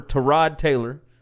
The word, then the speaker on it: Tyrod Taylor: They traded for Tyrod Taylor. Did I say that right? – You did.